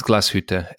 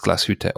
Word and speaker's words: Glashütte: So it's Glashütte, and I don't mind obviously people not pronouncing it correctly, but again, if you work in the industry, it's Glashütte or Glashütte if you can pronounce the Ü, because I'm guessing for English speakers or Americans it's a bit difficult to pronounce the Ü.